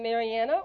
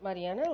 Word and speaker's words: Mariana: I'm Mariana, or if you can pronounce it Mariana, like Randy does so wonderfully.